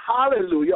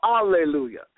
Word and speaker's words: hallelujah: is pronounced either hallelujah or alleluia. And this word, hallelujah or alleluia, whichever way you want to say it, if you want to keep the H silent or you want to say the H, literally means halal yah or halal jah.